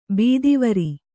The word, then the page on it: ಬುಧವಾರ: budha vāra